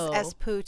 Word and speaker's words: Espoo: I thought it was Espoo too, because well, it's spelled E S P O O, but they pronounce it Espoo.